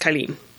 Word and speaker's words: Cailin: So, and it was originally spelled C-A-I-L-I-N and pronounced Kyleen.